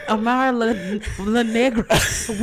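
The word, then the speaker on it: Amara La Negra: I never say her name right. Amara LaGrande isn't being extra at all. – Amara LaNegra. – Damn, I fucked that all up. – You said Grande.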